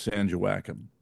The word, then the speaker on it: San Joaquin: And everybody knows it's San Joaquin.